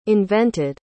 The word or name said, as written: invented